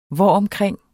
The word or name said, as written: hvoromkring